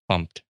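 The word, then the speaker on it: gofumpt: Like, it's just like, go fumped, right? I would never expect it to be pronounced fumped until I heard someone say it.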